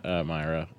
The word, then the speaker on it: Myra: I was like, my name's Matt Myra. You said Mira, but I mean, you can call me anything you want except cuntface.